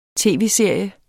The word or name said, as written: tv-serie